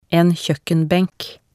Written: en kjoekkenbenk

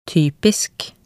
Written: typisk

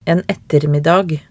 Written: en ettermiddag

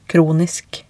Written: kronisk

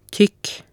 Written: tykk

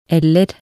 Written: eller

